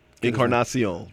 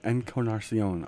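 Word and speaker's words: Encarnación: I always Encarnacion. – Yeah, Encarnacion.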